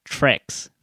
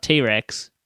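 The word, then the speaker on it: T. Rex: And also a great song by, apparently, Trex. Not T-Rex, Trex. If you watch this movie and he says what songs are named after her. – Yeah. – And they say the band T-Rex, but they pronounce it Trex.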